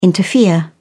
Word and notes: interfere: British English

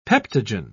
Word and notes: peptogen: British English

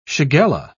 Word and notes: shigella: British English

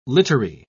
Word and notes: littery: British English

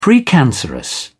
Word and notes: precancerous: British English

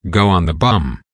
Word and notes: go on the bum: American English

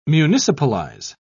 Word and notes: municipalize: British English

